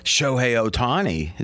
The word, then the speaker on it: Shohei Ohtani: You wanted to talk about Shohei Ohtani.